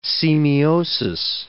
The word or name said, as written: semiosis